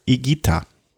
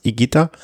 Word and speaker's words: Higuita: Jetzt kann ich den Vornamen nicht mehr aussprechen, weil am Tag Sergio Higuita. Also das H ist stimmlos und das U ist stimmlos.